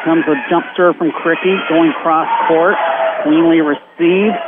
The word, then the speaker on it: Krichke: Oh, Cricky, I mispronounced it because I misspelled it, K-R-I-C-H-K-E, Cricky, is serving for the Comets.